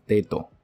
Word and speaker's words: dedo: 你们当然当地人，你们就去问你们的朋友，他们就会 dedo dedo。